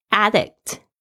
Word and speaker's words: addict: 它本来是从这个 addict 啊这个字动词来的，它也可以当名词的时候发音会不一样，会变成 addict，就对什么事物上瘾的人。